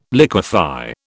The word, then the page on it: liquify: British English